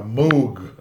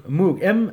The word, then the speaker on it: Moog: It's a whole new clarity, That's a very different word, a Moog. – Moog. I believe I'm pronouncing it correctly. It's M-O-O-G.